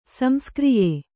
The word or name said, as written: ಸಂಸ್ಕ್ರಿಯೆ